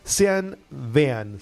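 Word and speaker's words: Sean Bean: Xian Vean se escribe y es Xian Vean.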